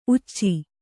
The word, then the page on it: ಊಚಿ: Kannada